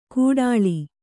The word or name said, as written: ಕೂಟಾಳಿ